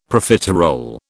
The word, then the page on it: Profiterole: British English